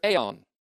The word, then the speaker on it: aion: That is, their life in the future aeon, A-I-O-N, long O, which will be eternal.